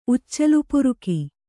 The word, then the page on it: ಉಚ್ಚಲುಪುರುಕಿ: kannada